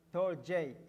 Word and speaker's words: storge: It's a storge, and it's pronounced torge in the Greek word.